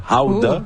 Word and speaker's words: gouda: Ou 3, le gouda, qu'il faut prononcer d'ailleurs howda. – Ooh, howda. – Howda? – Oui. En irlandais. C'est howda. Oui.